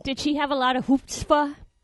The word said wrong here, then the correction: chutzpah: Did I say that right? Hoopspah? – You were close. Chutzpah.